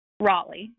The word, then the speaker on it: Raleigh: She's down in Raleigh, North Carolina. Is that how you say that? You say Raleigh, right? – Raleigh, yes.